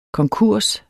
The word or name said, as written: konkurs